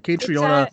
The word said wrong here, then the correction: Caitriona: It's Katrina. – I was putting a lot of like emphasis on that. Okay, Katrina Balfe.